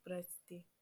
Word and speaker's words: Прости: Простый. Oh, I pronounced it wrong. Прости.